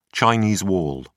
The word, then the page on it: chinese wall: British English